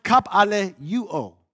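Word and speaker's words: kapaleuo (καπηλεύω): Now the word peddle is from a Greek verb, and it's pronounced kapaleuo.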